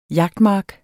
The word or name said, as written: jagtmark